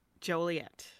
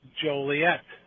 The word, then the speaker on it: Joliet: Your hometown, by the way, for people who might not know, Joliet.